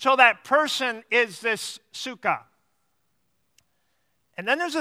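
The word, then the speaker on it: psyche (ψυχή): It's pronounced psyche. We sometimes pronounce it psyche.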